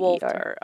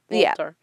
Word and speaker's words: Wolter: W-O-L-T-E-R. – Walter. Okay. Like wool. Walter. Yeah.